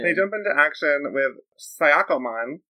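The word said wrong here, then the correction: Shakkoumon: I thought it was Shakomon. – Shakomon. It's spelled like Sayakomon. Pronounced like Shakomon.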